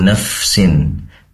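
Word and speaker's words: nafsin (نفس): Izin mengoreksi ketika membaca kullu nafsin. Naf lagi ya, pastikan fa ini mengalirkan nafas karena tempat keluarnya fa itu dari ujung lidah, maaf, ujung gigi seri atas ditempelkan ke perut bibir, bibir bawah ya.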